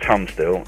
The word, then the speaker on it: Tunstall: My name is Mike Tunstall.